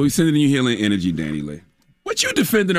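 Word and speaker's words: DaniLeigh: It's Dani Leigh.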